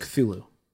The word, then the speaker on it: Cthulhu: I don't understand why, because it's just Cthulhu. That's just what it's been. I've heard Cthulhu, and I've heard just some other weird. It's Cthulhu.